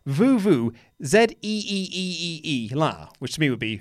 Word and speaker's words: vuvuzela: also says that Vuvuzela, which we've been talking about a lot, is actually pronounced Vuvuzela. The Z is the same as zebra, not the American Z.